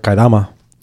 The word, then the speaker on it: Kaedama: So this place, I like to call it Kaidama. – Kaidama? – I just like to call it Kaidama. – Okay. But so what's it really called? – It's spelled K-A-E-D-A-M-A. So that to me sounds like Kaidama.